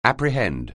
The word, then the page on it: apprehend: anglais